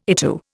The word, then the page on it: ITU: Indian